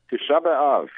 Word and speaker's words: Tisha B'Av: Let me ask, do you pronounce it Tisha B'Av? – Very good. – Okay. – You got it. Tisha B'Av is the, that's the ninth day of the Hebrew month of Av, and always comes out right around now.